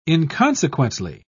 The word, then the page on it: inconsequently: British English